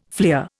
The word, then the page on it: fleer: Indian